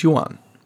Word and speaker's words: Yuan: and it's pegged to the Chinese Yuan, the third greatest currency in the world. – Excellent pronunciation there.